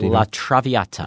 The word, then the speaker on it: La Traviata: La Traviata.